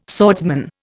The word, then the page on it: swordsman: Indian